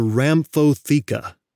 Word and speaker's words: rhamphotheca: The outermost layer of the beak, the one made of keratin, is called the rhamphotheca. Rhamphotheca, and that's spelled R-H-A-M-P-H-O-T-H-E-C-A. Rhamphotheca.